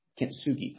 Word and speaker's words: kintsugi: I can't speak Japanese, but kintsugi, I think is how it's pronounced.